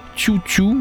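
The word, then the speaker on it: Xiu Xiu: En fait, son projet s'appelle. Euh, Chu Chu. en fait ça s'écrit euh, X-I-U, si vous préférez.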